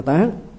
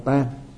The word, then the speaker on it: tán: Cái chữ này á, đọc là tán, một âm khác là đọc là tan à, có khi đọc là tan, khi đọc là tán, mà khi là tan á thì là nói về cái đám chết á, tang lễ đó, tang lễ, lễ tang đó ừ, hay là để phục tang, cư tang á. Nhưng mà trong một cái nghĩa khác thì nó đọc là tán, tán là tiêu hết,